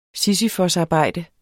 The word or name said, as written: sisyfosarbejde